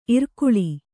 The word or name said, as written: ಇರಕಳಿ